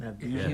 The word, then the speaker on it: Avdija: Denny Avdija, is that how you pronounce his name? Avdija. – Yeah.